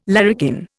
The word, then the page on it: larrikin: Indian